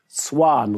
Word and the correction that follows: Seoane: richtig? Aus Bern? Seoane. Seoane.